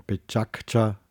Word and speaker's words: Pecha Kucha: Das kommt aus dem japanischen Pechakcha, wird es ausgesprochen, ähm, und bedeutet so viel wie Stimmengewirr, Geplauder, und darum geht es.